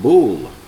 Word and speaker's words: Bul: In God's calendar, which is deleted from the world, nobody even realizes this, we're in the 8th month of God, called Bul, day 5. That's how it's pronounced. In English, you can say it's pronounced B-U-L, but it's B-O-O-L, really, in Hebrew.